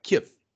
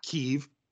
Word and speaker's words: Kyiv: Ah, so it's like, it's one of those deals where it's like K-Y-I-V or even K, Kiev. – Yeah. – But it's two syllables, but it's really fast. It's like Kiev. – Yeah. – Not Kiev.